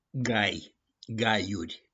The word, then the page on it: GAI: GAIURI